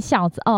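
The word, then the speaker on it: 硝子: 那前面呢，他呃着眼于学生时期的五条悟、夏油杰跟家人，这个念孝子吗？他他出场机会实在太少了，我不太知道怎么念，是一个石头一个烧吗？ – 孝孝孝哦，念孝子哦，OK OK，念孝子。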